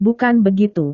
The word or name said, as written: bukan begitu